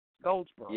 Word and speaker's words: Goldsboro: Goldsboro.